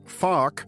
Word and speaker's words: FARC: better known and carefully pronounced as FARC, was flawed and muddled, but then pretty much all peace agreements are.